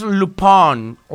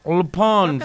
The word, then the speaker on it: Lupin: Se llama, bueno, se escribe Lupin, pero no como Lupis, que felicitamos por su cumpleaños. Lupin es Lupin. Lupin.